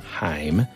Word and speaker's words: Haim: Like, I know there's like a lot of folks who listen to like heavier stuff that might think us liking, and the correct way to pronounce their last name is Haim.